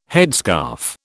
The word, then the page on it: headscarf: British English